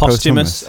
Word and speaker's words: posthumous: Post hummus. Have I said it wrong? Is it posthumous? I think, isn't it? – Posthumous. Oh, yeah.